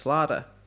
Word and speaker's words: Schlatter: And it was run by this guy called George Schlaughter, who was just a huge producer at the time. I mean, he was massive. – Schlaughter. – And Schlatter, probably it's spelled. I think we said George Schlatter.